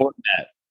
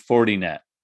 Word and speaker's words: Fortinet: Like, I never say that correctly. I want to say Fortinet, but it's 40 net.